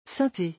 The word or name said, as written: synthe